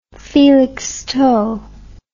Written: felixstowe

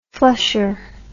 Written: flusher

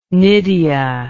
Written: nydia

